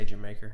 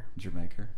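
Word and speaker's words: D'yer Mak'er: It's spelled like Dire Maker and you say Jamaica. – Jamaica, yeah.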